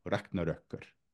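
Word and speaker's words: Ragnarökkr: And then you have the version that Snorri Sturluson employs in Edda, which is spelled A-R-G-N-A-R-Ö. So that's the O with the dots over it. And then K-K-R, Ragnarökkur. And that one means exactly the way that it's translated in Wagner's Wagneresque language, right?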